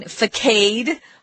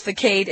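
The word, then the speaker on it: façade: A facade is pronounced in a strange way because it's a French word. So instead of saying facade, which you might think it should be pronounced facade, it's not. It's facade.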